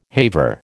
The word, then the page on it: haver: American English